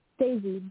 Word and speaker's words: Daizy: Daisy. – I can barely hear you. Can you spell it, please? – Daisy. D-A-I-Z-Y. – Oh, Daisy. Okay. D-A-I-Z-Y.